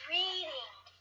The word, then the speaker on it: Reading: It's Reading.